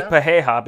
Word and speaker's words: Pereira: Pereira? – I say Pereira because that's how Pereira. In Brazil they would say it, because that's how you pronounce your R's as H's. So I think it's Pereira, but a lot of people call him Pereira.